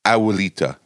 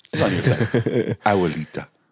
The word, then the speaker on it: Abuelita: And she says, Abuelita, soft B, okay. And don't forget to pronounce that U. Abuelita. – Abuelita.